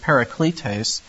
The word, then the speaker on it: parakletos: We said that it's the parakletos in the Greek. We pronounce it parakletes.